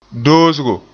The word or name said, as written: doosgo